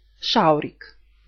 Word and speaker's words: schaurig: Шаурик. Есть слово траурик, а есть слово шаурик. Помните, как буква Ш пишется в немецком? SCH. Шаурик. Er ist schaurig traurig.